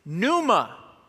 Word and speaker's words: pneuma: Or, watch this, in Greek, it's not ruach, it's pneuma. The P is silent. Pneuma.